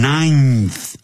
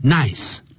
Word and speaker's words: ninth: 后头。没有什么重大的成就啊。就是他这种人呢，我刚才我在用英语在讲的时候，就等于 he was regarded as a good for nothing, 是一个这个窝囊废 good, G-O-O-D, 加个 hyphen, 短线符号，再加个 for, 一个介词，再放个短线符号，再放一个 nothing, a good for nothing, 就是一个窝囊废，是一个废物一个。After Arthur's ninth, 因为有一个N-I-N, 后头有个 N, 所以有鼻音 ninth, 不要念成 nice,